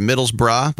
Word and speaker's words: Middlesbrough: It's funny, they're Middlesbrough. That's how you say it, Middlesbrough, but the nickname is Borough.